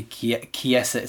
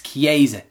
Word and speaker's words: Chiesa: It's got to be Chiesa. It's not. It's Chiesa. That's how we pronounce it.